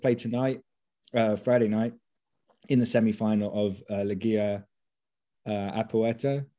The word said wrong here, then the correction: Apertura: Am I saying that right? Apertura. Man, every time.